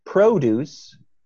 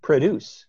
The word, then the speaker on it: produce: Is it a case that, so we have an alternation there, in case you didn't catch it, between produce and produce. And the part we're interested in is the first syllable where it's pro versus pra, produce versus produce. And the pra there, that's a schwa.